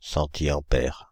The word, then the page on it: centiampère: /sɑ̃.ti.ɑ̃.pɛʁ/